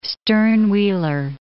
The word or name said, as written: stern-wheeler